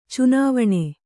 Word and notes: ಚಿನಿವಾನ: cinivāna; kannada

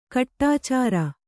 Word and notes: ಕಡೆಚೋರಿ: Kannada

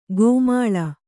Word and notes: ಗೋಮಾಳ: gōmāḷa

